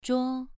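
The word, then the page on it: zhuo: tone 2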